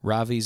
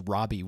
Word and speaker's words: Ravi: But anyway, if you guys want to check out any more of that, Ravi Zacharias, it's R-A-V-I. I thought it was Robbie when I first started listening the way people pronounce it.